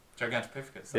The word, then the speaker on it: Gigantopithecus: Good on you pronouncing that. – Gigantopithecus. – Yeah, it's pretty good. – I said it a good few times. – Gigantopithecus. – Gigantopithecus. – Oh, there we go.